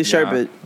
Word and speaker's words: sherbet: Is it really sherbet? – Yes. It's really sherbet.